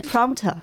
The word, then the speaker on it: Prompter: Prompter 好的，你可以给我们拼一下吗？